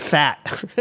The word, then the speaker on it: Fete: I almost didn't want to say anything about this because I wasn't happy with it, but this restaurant down in Chinatown called Fete, but it's spelled F-E-T-E, but I think they pronounce it fat because when I called and made the reservation, they said fat.